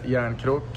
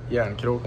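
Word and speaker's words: Järnkrok: My name is Kalle Jarnkrok, and that's how we pronounce it. Kalle Jarnkrok.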